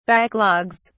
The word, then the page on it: backlogs: British English